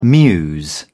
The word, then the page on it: muse: British English